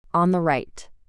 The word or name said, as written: on the right